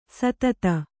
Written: ಸುದತಿ

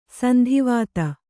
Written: ಸಂದಿವಾತ

